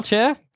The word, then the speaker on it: Cher: Well, Cher, guess what? – Guess what? You said her name right. – Did I? – Yep. – Oh, good.